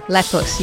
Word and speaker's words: Lepus: We're not French here. – Okay. – Lepus. You pronounce every letter in Latin.